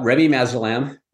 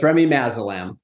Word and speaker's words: Remimazolam: We're talking about Remimazolam. Not as hard to pronounce as idarucizumab, but so it's Remimazolam.